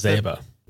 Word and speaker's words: Yazeba: I think Yazuba, is that how you pronounce Yazeba. – It? – Yazeba. Though they specifically say they don't care how you pronounce it.